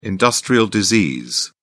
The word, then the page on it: industrial disease: British English